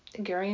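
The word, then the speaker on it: Gerry: And so Monday night, Gary and I, G-E-R-R-Y is pronounced Gary. I always have to remind you, sorry.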